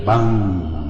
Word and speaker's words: VANG: V-A-N-G. Se pronuncia BANG.